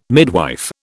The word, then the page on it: midwife: British English